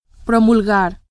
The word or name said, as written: promulgar